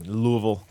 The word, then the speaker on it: Louisville: Denny is a professor at Boyce College, which is the undergraduate arm of Southern Baptist Theological Seminary in Louisville, Kentucky. You know it's pronounced Louisville, right? – I do. – Louisville? – No. – Not Louisville? – No, Louisville. – There's no Ville in it. It's Louisville.